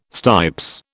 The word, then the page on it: stipes: British English